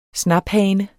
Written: snaphane

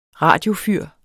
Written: radiofyr

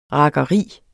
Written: rakkeri

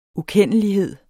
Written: ukendelighed

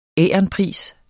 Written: veronica

